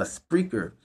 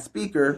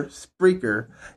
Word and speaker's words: Spreaker: What's going to happen is, if you are a loyal listener to the X-Rated Experience podcast and you have a Spreaker, and no, I said it correctly, not speaker, Spreaker. It's the platform where you can listen to us live while the actual event is happening.